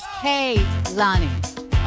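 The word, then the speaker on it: Kehlani: And just to clarify for everybody, it is Kaylani. Like Alani, you know, the energy drink, but with a K at the beginning.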